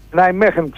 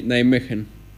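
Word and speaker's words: Nijmegen: Ναϊμέχεν, ξέρεις, έτσι προφέρεται. Κάπως ναι. Έτσι, Ναϊμέχεν. – Ναι, είναι... οι Ολλανδοί έτσι το λένε, Ναϊμέχεν.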